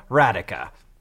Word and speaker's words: Radhika: Like radical without the L, Radhika."